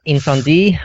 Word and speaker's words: Incendies: Incendie, I think. – Incendie, so is it French? – The French pronunciation is, yeah. Incendie, I think I've told you to watch that at least five times, you know.